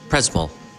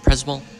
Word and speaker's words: Przemyśl: In could you pronounce exactly where we? It's Przemyśl. Przemyśl. This town where we are. – This Przemyśl. – Przemyśl. I'm sorry. – Przemyśl. I pronounce it completely different.